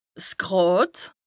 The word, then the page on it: 's Gràd: Bas-Rhin; Strasbourg